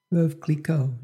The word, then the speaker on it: Veuve Clicquot: Veuve Clicquot? – Veuve Clicquot, yeah.